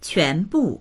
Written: quan2bu4

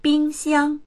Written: bing1xiang1

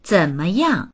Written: zen3meyang4

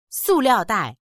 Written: su4liao4dai4

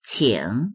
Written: qing3

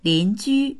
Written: lin2ju1

